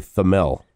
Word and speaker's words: Thamel: Is that how you pronounce his name? – Thamel. – Pete Thamel. He just posted a column of winners and losers from this year's coaching carousel. – I think it's actually Thamel.